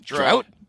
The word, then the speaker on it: Draut: Drought. Pretty close. Wow. Is it drought? Drought. Yeah. – Drought. Yeah, like not enough water.